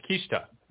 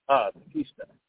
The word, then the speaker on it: Kushida: Kushida. – Say again? – Kushida. – Kushida. Thank you.